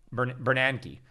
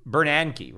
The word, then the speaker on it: Bernanke: That's where I met Bernanke. I always pronounce that guy's name wrong. Somebody was, you know, kind of call me out. Yeah, it's Bernanke, right? Not Bernanke, Ben Bernanke, something like that.